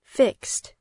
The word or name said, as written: fixed